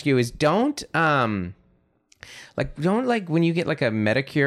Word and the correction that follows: manicure: Manicure. I said it wrong, actually.